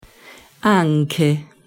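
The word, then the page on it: anche: Italian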